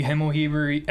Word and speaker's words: Himmelheber: Yeah, so you guys can find me on Twitter at E Himmelheber, at EHIMMELHEBER.